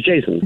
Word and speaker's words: Jason: J-A-S-I-N? – H-I-A-T-T. The first name is Jason. – Jason, like normal. J-A-S-O-N.